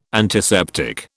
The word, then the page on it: antiseptic: British English